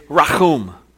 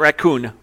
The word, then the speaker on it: rachum: The first is the word for compassionate means it's rachum. You don't have to say it like that. You can say rachum. Not raccoon, rachum. So rachum means there's a forgiving relationship there.